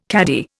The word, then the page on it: cadi: Indian